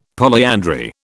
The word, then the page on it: polyandry: British English